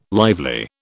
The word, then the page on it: lively: British English